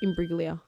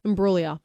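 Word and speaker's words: Imbruglia: Imbriglia. – Soft G. – Imbriglia. – Bang. – Imbriglia. Natalie Imbriglia.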